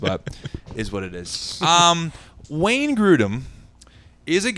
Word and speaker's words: Wayne Grudem: Wayne Grudem. I don't even know if I said his name correctly. – No, that was correct.